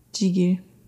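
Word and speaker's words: gigil: Jigil.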